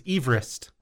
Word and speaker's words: Everest: The correct pronunciation of Mount Everest is Everest. – I have never heard that. – It's named after Sir George Everest, a surveyor from Wales, and that's how his last name was pronounced.